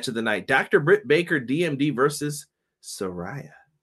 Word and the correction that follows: Saraya: or excuse me, Saraya Ray Saraya.